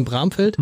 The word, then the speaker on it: Bramfeld: Es ist die Leiche einer Frau in, habe ich es richtig gesagt, in Bramfeld? – Mhm.